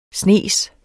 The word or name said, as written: snes